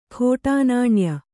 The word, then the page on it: ಖೋಟಾನಾಣ್ಯ: khōṭā nāṇya